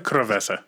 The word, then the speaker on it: cerveza: Did I say that right? Cerveza? Cerveza.